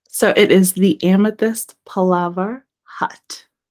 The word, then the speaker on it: Palaver: All right, so it is Amethyst Palaver Hut, Amethyst Palava Hut, and that's the same thing on Instagram as well.